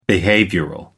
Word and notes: behavioral: British English

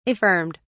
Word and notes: affirmed: British English

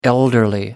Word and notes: elderly: American English

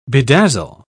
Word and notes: bedazzle: British English

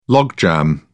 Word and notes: logjam: British English

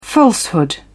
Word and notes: falsehood: British English

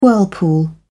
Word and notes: whirlpool: British English

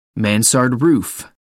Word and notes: mansard roof: American English

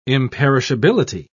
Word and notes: imperishability: British English

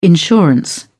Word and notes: insurance: British English

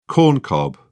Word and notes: corncob: British English

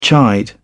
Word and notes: chide: British English

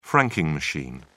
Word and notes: franking machine: British English

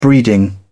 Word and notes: breeding: British English

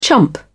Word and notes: chump: British English

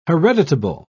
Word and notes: hereditable: British English

